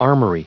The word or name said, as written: armory